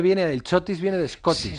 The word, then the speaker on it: Chotis: El típico, el muy madrileño Chotis, también a veces llamado Chotis con acento en la I, o al revés, ¿Es madrileño, es alemán o es escocés, como el whisky?